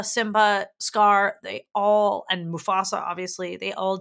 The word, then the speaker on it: Mufasa: Mufasa, because you have to say it like that.